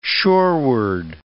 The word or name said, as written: shoreward